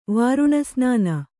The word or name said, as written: ವಾರುಣಸ್ನಾನ